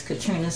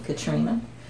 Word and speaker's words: Katrina: My name is Katrina Smith, spelled with a K, Katrina, on Facebook, and my email is smith1969, the letter K, at Gmail.